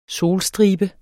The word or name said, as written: solstribe